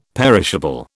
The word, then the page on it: perishable: British English